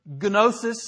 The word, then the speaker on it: gnosis: The Greek word is gnosis. It's spelled G-N-O-S-I-S. If you transliterate, it looks like G-V-O-N-I-S-I-S. But gnosis, some people call it, no, it's, just leave the G silent, it's gnosis.